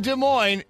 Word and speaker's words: Des Moines: They got a good slogan in Des Moines. It's like where the S is silent, but the nightlife isn't, or something like that.